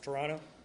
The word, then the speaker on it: Toronto: See, in Canada, the second T is silent. It's Toronto.